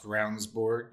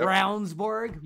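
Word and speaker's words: Ravnsborg: The behavior of the Attorney General, Jason Ravensbourg. Wow. – Pronounced Ravensbourg. – Yep. Ravensbourg.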